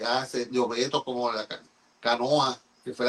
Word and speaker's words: canoa: Entonces, Colón decía, en su diario decía, ellos le dicen a esa fusta, una fusta es una embarcación allá en Europa, ellos le llaman canoa, con la última sílaba lengua, luenga, larga, esa canoa.